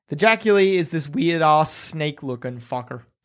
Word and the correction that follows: Jaculi: Oh, here's the pronunciation for it. Jaculi.